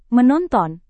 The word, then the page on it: menonton: Indonesian